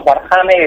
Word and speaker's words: Warhammer: ¿Warhammer? ¿Cómo es esto? ¿Esto qué es? – Pues es un torneo de cosplay, bueno, de juego de rol, por así decirlo. Se llama Warhammer, es el primer torneo de las Dragons y lo organiza una asociación que se llama Lorde del Drag.